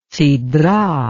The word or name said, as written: sedrah